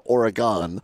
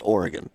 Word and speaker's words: Oregon: Problem is that the suburb of Madison, they pronounce it Oregon, and the state is Oregon.